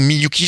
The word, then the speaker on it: Miyuki: Bijoux Miyuki, j'ai bien prononcé ? – Oui, c'est bien ça.